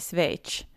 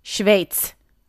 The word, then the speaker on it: Schweiz: Um, det var egentligen en byteshandel när han, skulle flytta till Schweiz. Uttalade det rätt nu. Schweiz är det.